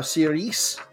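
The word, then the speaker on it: sūris: S-U-R-I-S. – All right. So S-U-R-I-S. So we think it's pronounced Siris. – Very cool. So that's cheese. – On the I. – Oh, siris. – Yes. – So you got to get your emphasis on the right syllable.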